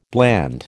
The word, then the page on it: bland: American English